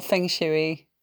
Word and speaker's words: Feng Shui: Do you know, it's Feng Shui or Feng Shui. It still means wind and water, whichever way you say it.